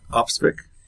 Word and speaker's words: Opsvik: Opsvik.